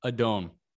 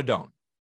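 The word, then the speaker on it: Adon: How do you pronounce his name? – Adone. – Yoan Adone.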